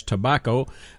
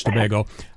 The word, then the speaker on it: Tobago: I've always mispronounced that as tobacco, but that just the way I am sometimes. I mess up on words. Really, it's Tobago.